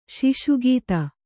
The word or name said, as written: ಶಿಶುಗೀತ